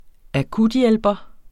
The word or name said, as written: akuthjælper